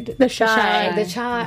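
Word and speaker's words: The Chi: Am I pronouncing this right? Chi? Is that how they? – The Chai.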